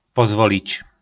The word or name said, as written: Pozvolic